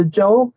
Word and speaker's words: Zhou: The second dynasty in this list is the Zhou. The Zhou dynasty is spelled Z-H-O-U, and Z-H in that situation is just kind of like an English J.